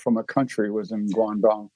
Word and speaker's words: Guangdong: Now, this mainly impacted the ports across the Chinese province of Guangdong. If I said that right, Guangdong. Yeah, it's Guangdong.